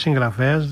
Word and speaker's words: συγγραφέας: Η Μπέτη μας λέει το εξής: με μεγάλο σεβασμό και αγάπη θέλω να σας επισημάνω πως η λέξη συγγραφέας δεν προφέρεται όπως γράφεται. Προφέρεται με διακριτό νι.